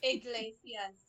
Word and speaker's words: Iglesias: Iglesias. I-G-L-E-S-I-A-S. Iglesias. It's spelled exactly how it sounds.